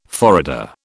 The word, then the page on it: forrader: British English